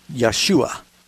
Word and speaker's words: Yeshua: The name Jesus is, of course, when you read the New Testament, you're reading the Greek pronunciation. It's in Hebrew, his name is really Yeshua. In Greek, it comes out Jesus, or something close to that.